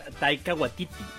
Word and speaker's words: Taika Waititi: El malo es, ahí ayúdame con el nombre, ¿cómo se pronuncia? – Fíjate, me encanta. – Taika Waititi, ¿o cómo? – Taika Waititi.